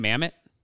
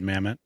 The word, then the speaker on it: Mamet: But Al Pacino was a big fan of, how do you say his name? Mamet? – Yeah, David Mamet.